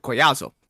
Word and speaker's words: Collazo: It looks like Collazo, but if it's Hispanic, two Collazo. L's is a Y sound, so Collazo.